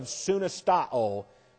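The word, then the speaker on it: Sunistao (συνιστάω): And in the Greek, it's the third person singular, present active, indicative of sunistao. And it looks like this. S-U-N-I-S-T-A-O. Sunistao. S-U-N-I-S-T-A-O. Long O on the end.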